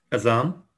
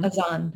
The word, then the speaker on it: Adhan: So we'll call ourselves to prayer through this beautiful, it's called the Adhan sometimes and the Azan. – Azan. – Mm-hmm. Azan is how you say it, yes. And I think it's spelled A-D-H-A-N, is that right? – Correct.